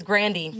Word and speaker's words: Grande: It's not Grande. It's Grandy.